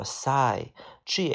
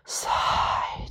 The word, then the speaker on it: sigh: 下一个单词叫做 sigh，G H 往往字母组合是不发音的，那么 S 发四，I 发 I，sigh 就是叹息。 sigh 就这个单词啊，sigh，OK 啊，好，下一个单词叫做 silly，silly 就傻了吧唧的，比如说一个傻了吧唧的男孩，a silly boy，哎，你是不是傻了呀？